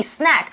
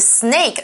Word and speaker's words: snack: Okay, 这里的 late night snack, 其中的 snack 其实就是一个小点心啦，或者是说你不是正餐时候吃的一个小东西哦。Okay, 那 late night snack 当然就是宵夜的意思。那注意 snack 发音不要发成 snake, 那是蛇肉。